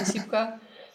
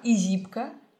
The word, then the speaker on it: ISIPCA: Правильно произнос... произношение, правильное произношение этого слова это Исипка, Исипка, Изипка. – Ну, если говорить на французский манер, то ударение всегда на последний слог, то есть получается Изипка.